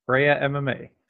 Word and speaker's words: Freja: Freja MMA.